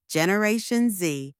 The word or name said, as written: Generation Z